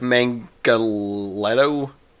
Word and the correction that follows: Manganiello: Mangaletto? – There is no T in the last name. – Then you pronounce it. – I mean, Manganiello?